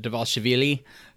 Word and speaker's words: Dvalishvili: We had, as expected, Mirab Dvalishvili. How do you like that pronunciation there, buddy? – Not bad.